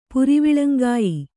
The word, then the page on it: ಪುರಿವಿಳಂಗಾಯಿ: puriviḷaŋgāyi